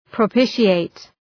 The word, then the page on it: propitiate: prə’pıʃı,eıt